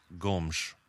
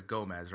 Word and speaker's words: Gomes: Yeah, it's pronounced Gomes, right? Like, that's how it's pronounced. But, you know, we try – like, when I see it, I automatically says Gomez, right? Because that's just, like, the Spanish style.